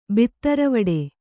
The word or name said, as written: ಬಿತ್ತರವಡೆ